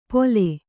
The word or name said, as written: ಪೊಲೆ